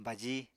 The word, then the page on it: baji: Apatani